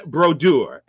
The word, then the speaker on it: Brodeur: So that leaves number one, and hats off to Martin Brodeur, as it must be pronounced.